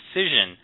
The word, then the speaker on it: Cision: Oh, Cision. – See, that's why I'm saying it wrong. Just have to say it right. Cision.